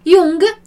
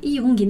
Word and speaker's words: Jung: A gente não fala Jung. Porque também aqui tem uma origem estrangeira, né? Jung. Então, ficou assim nos dois idiomas. Mas assim seria como os italianos já pronunciariam, né? Porque a gente viu que o J tem esse som de I. Pode ter esse som de I, né?